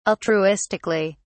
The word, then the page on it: altruistically: British English